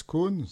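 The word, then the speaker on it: Kohn: Bah écoutez, je m'appelle Max Cohn, ça s'écrit avec un K, c'est Cohen.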